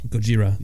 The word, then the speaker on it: Gojira: Gojira.